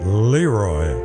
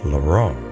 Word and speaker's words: Leroy: I found out that's how you pronounce it. Found out from his wife. It's not Leroy, Lou. It's Leroy.